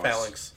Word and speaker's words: phalanx: Phallus. – Phalanx. – Phalanx? – Phallus. – Phallus? Where the phallus? Where the little... – You're not pronouncing the end of X.